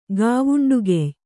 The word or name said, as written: ಗಾವುಂಡುಗೆಯ್